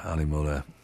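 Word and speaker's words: Ale Möller: And his name is not spelt the way it sounds. Ali is spelt A-L-E, as in beer, and Möller is M-O umlaut, right? So the two dots over the O, M-O-L-L-E-R, Ali Möller.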